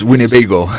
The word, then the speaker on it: Winnebago: ¿Lo he pronunciado bien? No lo tengo muy claro, ¿eh? – Es Winnebago. – Winnebago, Winnebago, ¿ves? Ya decía yo.